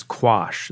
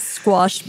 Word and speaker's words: quash: So if you ever take a, you know, a trivia test on legal terms, the word is quash. There's no S.